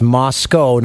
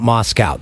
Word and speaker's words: Moscow: Because in my school, I've said this before, the biggest thing I learned was be on time, and it's pronounced Moscow, not Moscow.